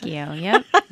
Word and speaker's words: radicchio: And radicchio, I guess. – Radicchio, yep.